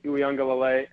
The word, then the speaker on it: Uiagalelei: I mean, everybody saw, you mentioned what DJ Uiagalelei, and I think I said that right the first time, so perfect.